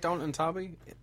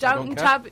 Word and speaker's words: Downton Abbey: The only time I ever liked Downton Abbey. No, I said the name wrong, so Downton Abbey.